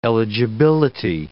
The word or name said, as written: eligibility